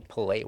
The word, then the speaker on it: Pelé: You say Pelé. Everyone also knows says Pelé. – Yeah. Because he says cock. Everybody says cock. – It's true. – It does have an accent on the second E.